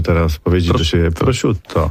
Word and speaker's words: prosciutto: Prosciutto, prosciutto, prosciutto.